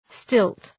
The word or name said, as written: stilt